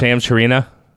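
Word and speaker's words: Shams Charania: Am I saying his name right? Yeah. Shams Chirina? Chirina? Yeah. All right, there you go.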